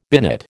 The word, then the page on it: binate: American English